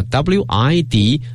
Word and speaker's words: width: 但是呢变成名词的话呢，要把这个 wide 进行一定的变化，变成了 width，发音也发生了变化，从这个 wide 变成什么 width， 33.1米啊这么一个宽度。